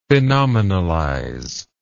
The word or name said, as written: phenomenalize